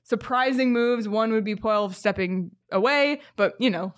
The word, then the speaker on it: Poile: David Poile, that's how you say his name, right? – Yep.